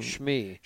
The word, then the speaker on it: Shmi: Shmi.